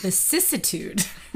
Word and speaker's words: vicissitude: Vicissitude. – There are a lot of syllables in that. – There are. It's a sneaky one. That C straight to the ISS really gets you.